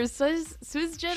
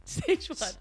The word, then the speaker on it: Szechuan: I never know how to pronounce the name of this sauce. Swishin or Swizjin? How do you pronounce it? – Szechuan.